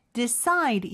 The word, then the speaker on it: decide: 그 다음에 decide, 이음절의 강세가 있고요.